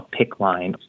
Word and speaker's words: PICC: The next step up is something we call a PICC line. It's spelled PICC.